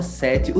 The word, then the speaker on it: Sete: Laura Sete. O teu nome é Laura Sete mesmo? Se pronuncia dessa forma? – É, como sete, como número mesmo, sete em italiano.